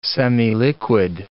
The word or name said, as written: semiliquid